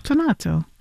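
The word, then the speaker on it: tonnato: Tonato. How do you say it, first of all? – Tonato.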